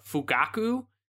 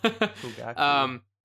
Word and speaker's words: Fugaku: and it's called the Fugaku, and it was the... I butchered that, I'm sorry. Yeah. That was horrible. Fugaku.